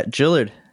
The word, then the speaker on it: Jillard: Yeah, Jillard.